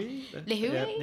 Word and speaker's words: Lihue: There are flights from the US and they arrive into the main city, which I think is pronounced Lihue, but I'm sure I've probably got that wrong. Lihue? – Yeah.